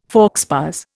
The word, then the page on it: faux pas: Indian